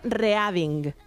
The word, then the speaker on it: Reading: Yo hubiera dicho Reading. Vamos a la ciudad de Reading. ¿Cómo se dice de verdad? – Reading. – Reading. – Reading. – Y es que el pasado del verbo leer, que es read, read, read, aunque se escribe igual, tiene diferencia de pronunciación, pues esta es una de aquellas. Es reading. – Reading.